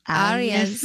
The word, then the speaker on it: Arias: Is it Arias? Is that how you say your last name, Lucy? – Arias. – Thank you. I can't roll my R, so I'm sorry about that.